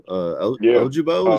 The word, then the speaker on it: Ojabo: Ojabo, is that how it's pronounced? – Yeah.